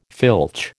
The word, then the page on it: filch: American English